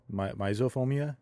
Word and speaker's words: myxophobia: I'm hoping this is pronounced myophobia, myxophobia, M-Y-X-O phobia, myxophobia.